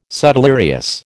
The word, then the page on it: subdelirious: American English